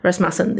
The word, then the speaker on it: Rasmussen: Is that how you say her name? Rasmussen, there you go.